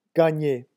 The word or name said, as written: gagner